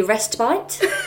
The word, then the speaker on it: respite: For anyone listening that doesn't know what that word means, because I wouldn't understand it either, it's actually respite. Correctly pronounced. – Not rest and bite. – Not rest and bite. It's correctly pronounced respite. But that don't make no sense to me. So respite, for anyone listening.